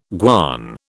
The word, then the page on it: guan: British English